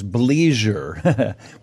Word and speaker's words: bleisure: And the topic is bleisure, which is a phrase I haven't heard before. Heather, welcome to the show. – Thank you for having me. – Am I pronouncing that correctly? Bleisure? – Exactly.